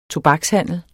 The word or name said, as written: TOBAKSHANDEL